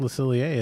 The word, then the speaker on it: Le Cellier: I probably mispronounced Le Cellier as well. So. – No, I'm pretty sure that's how you pronounce it.